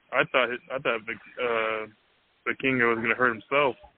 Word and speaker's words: Vikingo: And watching Viking, how do you pronounce it? Viking? – Yeah, Vikingo. – Vikingo, yeah. Vikingo.